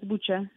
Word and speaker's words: Bucha: executions in Bucha. Is that how you pronounce it? – Yes, it's Bucha.